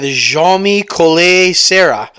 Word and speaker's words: Jaume Collet-Serra: Let's go with Jaume Collet-Serra.